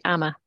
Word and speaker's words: Amma: Amma. Now, am I saying that correctly? – Yep, that's right. Amma.